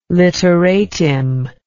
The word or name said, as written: litteratim